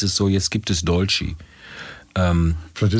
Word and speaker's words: dolci: Und äh, Dolce, Dolci, wie heißt es richtig? Dolci. Dolci, mhm. e wie i ausgesprochen? Ja, Dolci.